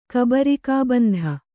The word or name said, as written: ಕಬರಿಕಾಬಂಧ